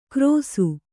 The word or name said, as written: ಕ್ರೋಸು